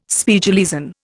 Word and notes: Spiegeleisen: Indian

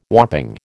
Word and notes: whopping: American English

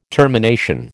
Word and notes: termination: American English

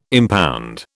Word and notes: impound: British English

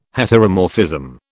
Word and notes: heteromorphism: British English

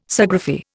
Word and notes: Sciagraphy: Indian

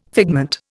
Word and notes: figment: Indian

